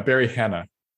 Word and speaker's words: Hannah: Hannah, Barry Hannah. – Oh, Barry Hannah. – Okay. Yeah. Like Hannah. – Like the lady's name.